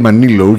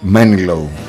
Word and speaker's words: Manilow: Στην Ελλάδα το ξέρουμε ως Barry Manilow, γιατί τονίζουμε όπως μας βολεύει τα αγγλικά εδώ πέρα, τα αγγλικά ονόματα.